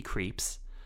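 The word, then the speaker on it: Krieps: While having one such vacation, he meets a woman named Alma, played by a new actress named Vicky Creeps.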